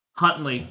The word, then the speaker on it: Huntley: How do you say his last name? Something like that? – Huntley, yep.